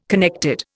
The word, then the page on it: connected: Indian